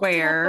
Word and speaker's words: Weir: Like Johnny Weir, mm-hmm. – the figure skater. Mm-hmm. Are we going that way? Okay. So Anissa Weir